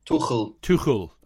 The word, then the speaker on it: Tuchel: Tuchel.